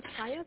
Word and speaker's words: Chaya: My first name is Chaya. That's spelled C-H-A-Y-A.